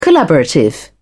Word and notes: collaborative: British English

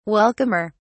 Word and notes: welcomer: British English

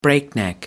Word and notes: breakneck: American English

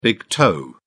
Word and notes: big toe: British English

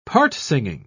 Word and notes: part-singing: British English

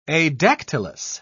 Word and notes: adactylous: British English

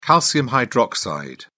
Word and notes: calcium hydroxide: British English

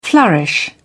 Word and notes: flourish: British English

